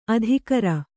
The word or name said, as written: ಆದಿಗಿರಿ